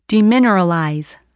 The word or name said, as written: demineralize